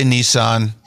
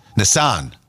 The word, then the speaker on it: Nissan: We say Nissan. Britons say Nissan. – Nissan. – Both have it wrong. Emphasis on the second syllable. It's Nissan.